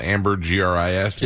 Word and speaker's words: ambergris: Anybody else want to guess what ambergris might be? And I might may not be saying it right, but that's how it's spelled. Amber G R I S. – It's ambergris. marmalade.